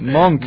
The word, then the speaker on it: Monk: Miran que es sin F, con M, Monk.